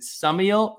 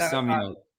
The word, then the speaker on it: Summiel: Before I pronounce it wrong, literally the entire podcast, and for everybody that's listening, is it Summiel or Summiel? – You said it perfect the first time, Summiel. – Perfect. Olivia Summiel.